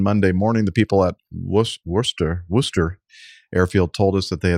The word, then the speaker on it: Worcester: That'll be Worcester. – Worcester, excuse me. They pronounce it like that in South Africa too, huh? – Well, pretty much everywhere. – They copy the British pronunciation.